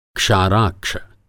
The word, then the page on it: क्षाराक्ष: Hindi